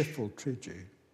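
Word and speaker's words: Pfifltrigg: It's pronounced Fiffle Trigi? – Fiffle Trigi. – Now I can die, because I've always wondered. Fiffle Trigi. – Yes, that's right.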